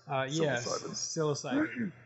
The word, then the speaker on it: psilocybin: Was it psilocybe? – Psilocybin.